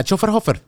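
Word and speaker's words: Schöfferhofer: tú dices la Schofferhofer. La Schofferhofer, sí, algo así.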